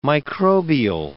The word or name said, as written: microbial